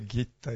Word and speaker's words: Gitta: Il libro si chiama di Gitta, si scrive, mi hanno detto che si pronuncia Gitta in ungherese, e Malast si scrive, si pronuncia Malos, credo.